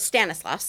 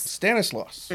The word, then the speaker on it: Stanislaus: Stanislaus. – Stanislaus.